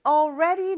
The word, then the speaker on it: already: 발음이 조금 어렵죠. All 하면서 혀가 윗니 뒤쪽에 와서 딱 붙어야 돼요. 그런데 R 발음하면서는 이제 떨어져야 되는 거죠. Already.